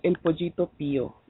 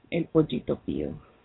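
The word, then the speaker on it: El Pollito Pío: It's Pollino Pio? – No. The song is called El Pollito Pio. El Pollito Pio.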